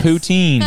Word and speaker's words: Poutine: Poutine. Is that what you're thinking of? Yes. Poutine.